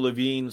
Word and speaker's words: Levine: Man of the Year award to Rachel Levine, or what, how you say her name? – Rachel Levine.